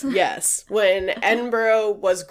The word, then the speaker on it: Edinburgh: Oh, it's Edinburgh. So it looks like Edinburgh, but it's pronounced Edinburgh.